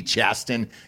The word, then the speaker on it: Chasten: I'm pronouncing that T, Chasten. You haven't earned the respect for me to give you a soft T right off the bat, homie.